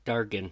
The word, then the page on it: Valmiki: wahl-MEE-kee